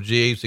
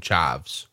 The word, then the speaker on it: Jeeves: Dusty mispronounced Regal's second Jeeves. Instead of calling him Jeeves, he calls him Chives.